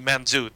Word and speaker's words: Mangetout: Wet Leg and Men Get Out. I know it's supposed to be Manzoot, but it's spelled in Anglo-English as M-A-N-G-E-T-O-U-T.